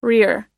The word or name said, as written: rear